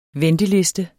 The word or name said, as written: venteliste